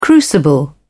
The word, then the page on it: crucible: British English